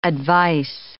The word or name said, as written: advice